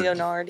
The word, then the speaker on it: Lynyrd Skynyrd: Lynyrd Skynyrd. – Wow, but it probably wasn't spelled like that. – It was spelled L-E-O. – Lynyrd. – Lynyrd. – Leonard, yeah. – Yeah, Lynyrd Skynyrd.